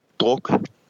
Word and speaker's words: Druk: It's called Another Round, or how do you pronounce this in Danish? Is it Druk? – Druk.